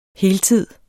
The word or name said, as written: heltids